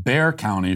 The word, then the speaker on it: Bexar: Matthew says, Bexar County is pronounced Bear County for future reference.